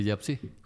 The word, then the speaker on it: Jiapsi: ¿Cómo se escribe Yapsi? J-I-A-P-S-I.